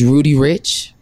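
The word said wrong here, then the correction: Roddy Ricch: Okay. – Roddy? Roddy Rich.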